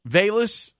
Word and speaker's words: Velus: is it Velus? – Velus Jones Jr. Velus, okay. Velus, okay.